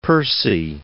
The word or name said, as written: Percy